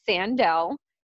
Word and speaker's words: Sandel: So my first name is Sandel. So like San and Del.